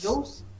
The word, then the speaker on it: Jules: Você eu falei, a, Juice? Jules? Uhum. – Ah, então desculpa, falei, é, falei errado.